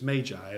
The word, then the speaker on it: magi: We always say magi, but it's my understanding it's magi, as in M-A-Y-J-I. You know, we spell it M-A-G-I, but it's pronounced like magi.